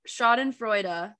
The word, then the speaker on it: Schadenfreude: Yeah, Schadenfreude.